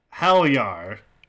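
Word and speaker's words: Heljar: We got an email from Helyar. I believe the pronunciation, based on some Google searching. If not, I apologize. – Yeah, real bad at names anyways, so... – You're not wrong. – And I don't see the emails, so sorry, whoever you are. – It's H-E-L-J-A-R is how it's spelled.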